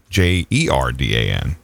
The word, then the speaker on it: Jordan: When I went to Columbus, Georgia, the name Jordan, of course, anywhere else in the free world is Jordan, but not there. Nope, it's Jordan, Jerdan. That's how it's pronounced.